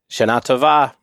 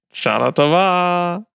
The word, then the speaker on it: Shana Tova: Shana Tova. I put the emphasis elsewhere. I say Shana Tova. – Shana Tova. Well, I'm looking at the web and it says the second syllables are in capital letters, but I hope as your Gentile friend, I can say that to you on your New Year's.